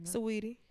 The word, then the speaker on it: Saweetie: Saweetie. – Saweetie? – It could just be Sweetie, but it's spelled Saweetie.